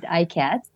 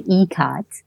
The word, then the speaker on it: Ikat: I know some people pronounce it ICAT, but it's actually ECAT.